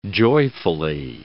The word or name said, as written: joyfully